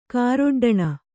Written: ಕಾರೊಡ್ಡಣ

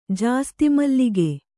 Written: ಜಾಸ್ತಿಮಲ್ಲಿಗೆ

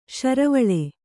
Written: ಶರವಳೆ

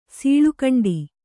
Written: ಸೀಳುಕಂಡಿ

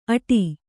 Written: ಅಟಿ

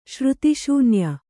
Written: ಶ್ರುತಿಶೂನ್ಯ

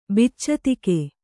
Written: ಬಿಚ್ಚತಿಗ

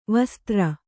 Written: ವಸುಧಾರೆ

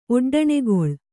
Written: ಒಡ್ಡಣೆಗೊಳ್

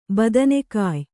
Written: ಬದನೆಕಾಯಿ

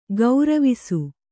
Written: ಗರುವಿಸು

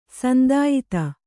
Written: ಸಂದಾಯತ

